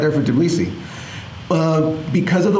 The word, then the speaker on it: Tbilisi: Tbilisi.